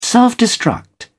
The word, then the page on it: self-destruct: British English